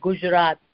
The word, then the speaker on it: Gujarat: Okay, so my state, the state that I come from is called Gujarat. – Gujarat, okay.